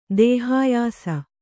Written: ದೇಹಾಯಾಸ

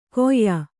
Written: ಗೊಯ್

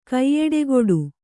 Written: ಕೈಯೆಡೆಗೊಡು

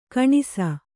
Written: ಗೊಣಸು

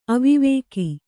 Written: ಅವಿವೇಕಿ

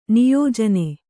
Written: ನಿಯೋಜನ

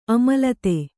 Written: ಅಮಲತೆ

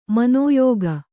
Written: ಮನೋಯೋಗ

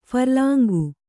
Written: ಫರ್ಲಾಂಗು

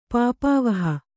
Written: ಪಾಪಾವಹ

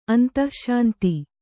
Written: ಅಂತಶ್ಶಾಂತ

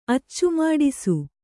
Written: ಅಚ್ಚುಮಾಡಿಸು

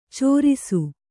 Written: ಚಾರಿಸು

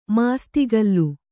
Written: ಮಾಸ್ತಿಕಲ್ಲು

